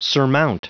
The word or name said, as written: surmount